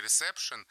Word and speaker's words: ресепшн: Там буква «е» пишется, и там «шн» на конце в слове «ресепшн» вовсе не такое «шн», как, например, в слове «шнур». Там есть какой-то неуловимый Сережа.